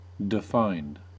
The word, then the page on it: defined: American English